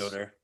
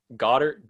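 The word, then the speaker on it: Goedert: Tight ends, we got Goddard. I don't even know how to say his name. Goddard, 24 points. – Goddard. – Yeah. Goddard, that's it.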